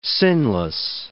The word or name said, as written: sinless